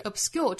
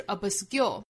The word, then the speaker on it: obscure: 后半句的一个要点就是 obscure 这个词里面这个 b 的发音不要十足爆破啊，不是 obscure 而是 obscure, obscure 相当于只上下嘴唇眯一下之后就过渡到后面的音了，不能真正的爆破发 b， b 不是 ab， ab 而是 ab， ab 在这个位置憋一下 obscure, obscure。